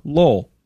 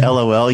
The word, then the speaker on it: LOL: Lol. – I love that you don't say LOL. You say it like the name LOL.